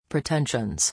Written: pretensions

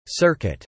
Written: Circuit

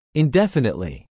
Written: indefinitely

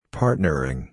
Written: partnering

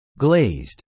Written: glazed

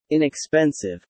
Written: inexpensive

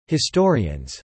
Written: historians

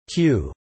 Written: cue

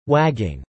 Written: wagging

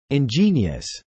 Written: ingenious

